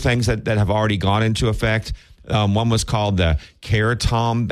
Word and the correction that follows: kratom: Ban, Act 41, a complete ban on the possession, sale, and distribution of kratom. I may be saying that wrong. – Kratom? – Kratom. – Okay. – K-R-A-T-O-M.